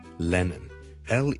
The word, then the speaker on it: Lennon: 第一个字咧就系、是、人名嚟嘅，John Lennon，John Lennon，John Lennon 一个英国嘅好出名嘅歌手同作曲家啦，Lennon，L E N N O N。第一个是 L 音，所以唔好将佢读成 N 音，唔系 Lennon，系 Lennon，Len，Len，Len，Len，Len，Len，Len，Len，Len，后面嗰个先系 N 音，所以 Lennon 仲要两个 N 音添，Lennon 第一个 L，Len，Len，Len，Len，Len，Len，Len，Len，Len，Len，所以 John Lennon 大家练一练，John，John 当然系 N 音味啦，又更加搞乱啦，John。